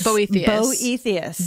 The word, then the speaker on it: Boethius: And him talking about Boethius, Consolation of Philosophy, and being like how that book is like divine. And then I, like a little bitch. – Boethius? – Boethius. Boethius, I believe.